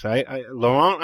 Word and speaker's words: Laurent: You know, the Americanized way is Laurent Bignon. – I think that's pretty close. Laurent, I think, is right. And no, it's not. – It's not. – Because if you ask him, he'll say Laurent Bignon.